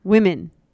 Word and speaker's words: women: Like whim. Like on a whim, yeah. Women, and women. Then woman. – Woman. – Is one woman.